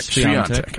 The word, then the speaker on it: Świątek: Świątek. – I suck at tennis names. Every single ticker I do at tennis when I have to ask DJ how to pronounce it. – Nailed it.